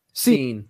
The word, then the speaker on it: Cine: But at the same time, a player that I really like, and you'll have to tell me if I'm pronouncing his name right, Lewis Cine, is it right? Is that how you say it? C-I-N-E? – Cine.